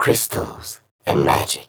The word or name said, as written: Chrystals and Magic